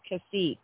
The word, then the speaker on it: Cacique: It's actually pronounced Cacique. The T is silent.